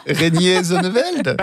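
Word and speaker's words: Reinier Zonneveld: Oh mince, comment est-ce qu'on prononce ça? Reiner Zonneveld. – Ouais, un truc du genre. – Reiner Zonneveld. – Perfect.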